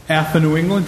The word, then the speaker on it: AFA New England: And then we're going to welcome in some guests from AFA New England. Did I say that right, ladies? – Yes, you did.